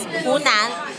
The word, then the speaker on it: Hunan: Is that how you pronounce it? – Yes, Hunan.